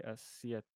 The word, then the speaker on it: aceite: Oh, by the way, the Spanish phrase I was looking for was Cambio de Siete. Siete? I butchered it. Cambio de Aceite.